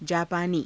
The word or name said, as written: jaapaanii